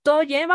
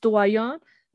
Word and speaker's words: Toyen: Y ahora vamos a Toyen, vamos a decirle Toyen en español, así se escribe con Y, pero la pronunciación es Tuayon, de como, como una contracción de Situayon, De la palabra eh, ciudadanos en, en francés.